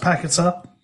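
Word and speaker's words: Paquetá: He's named after that area, and that area is pronounced Paqueta.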